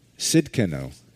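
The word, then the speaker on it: Tsidkenu: The Jehovah, how do you pronounce it again? Sidkenu. Thank you.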